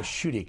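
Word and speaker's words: Schuette: We're in the Schutte case, if that's the way you pronounce it. – Yes, that's exactly how you pronounce that.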